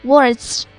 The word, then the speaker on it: Waltz: Waltz, W-A-L-T-Z 发音很像华尔兹，实际上它就是这个意思，华尔兹舞曲。